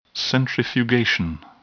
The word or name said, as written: centrifugation